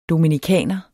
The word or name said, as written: dominicaner